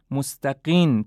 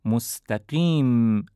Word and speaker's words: مستقیم: تجربه نشون داده مثلا کلمه اهدنا الصراط المستقیم رو مستقین تلفظ میکنن، مستقین یعنی آخرش با حرف نون، در حالی که این کلمه آخرش حرف میم هست. مستقیم صحیحه نه مستقین.